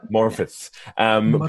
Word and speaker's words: Morfydd: And then, as you said, there was a particular moment between Jennifer and Morfydd. I hope I'm pronouncing her name correctly. – On the end, it's a bit more like Morfydd. – Morfydd.